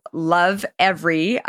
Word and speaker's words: Lovevery: Entrepreneur here, Jessica Rolph, who is the co-founder and CEO of this amazing, amazing company called Lovevery. Hopefully I'm pronouncing it correctly. – You are.